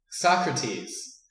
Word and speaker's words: Socrates: the setting was a symposium, a drinking party, where, I'm trying really hard not to mispronounce his name, Socrates.